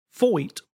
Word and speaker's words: Feucht: F-E-U-C-H-T, which I pronounce his name, parentheses, pronounced Foyt.